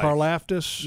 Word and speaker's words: Karlaftis: Karlaftis, yes. I think is how you pronounce his last name. – Correct. George Karlaftis.